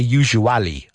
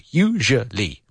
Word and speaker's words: usually: But notice the pronunciation here of the adverb of frequency. Usually. I hear usually a lot, but no, usually. Three syllables, usually.